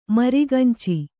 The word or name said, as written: ಮರಿಗಂಚಿ